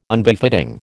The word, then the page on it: Unbefitting: American English